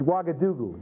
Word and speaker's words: Ouagadougou: Once you hear it, it's pretty simple. Huh? It doesn't look like that. But anyway, it's Ouagadougou. They call it Ouaga.